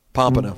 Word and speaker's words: Pompano: Pompano.